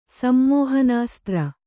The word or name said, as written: ಸಮ್ಮೋಹನಾಸ್ತ್ರ